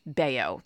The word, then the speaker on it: Bello: I should know that B E L L O, when the player is Dominican, is probably, yeah, pronounced Bayo.